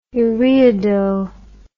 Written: ureido

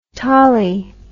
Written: tolley

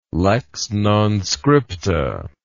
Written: lex non scripta

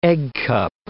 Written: eggcup

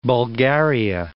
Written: Bulgaria

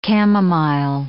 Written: chamomile

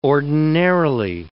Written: ordinarily

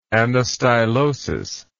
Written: anastylosis

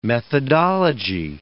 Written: methodology

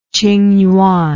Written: qingyuan

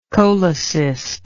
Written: cholecyst

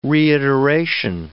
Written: reiteration

